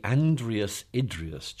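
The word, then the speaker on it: Andreas Idreos: It's the Andreas Idrius Chair of Science and Religion at Oxford.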